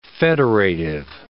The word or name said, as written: federative